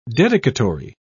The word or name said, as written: dedicatory